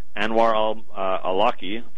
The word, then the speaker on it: Anwar al-Awlaki: An American citizen, Anwar al-Awlaki, that's how you pronounce his name, was assassinated.